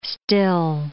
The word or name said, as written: still